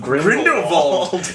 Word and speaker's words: Grindelwald: But they can't pull the wonderfully titled The Crimes of Grindelwald.